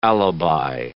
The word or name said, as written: alibi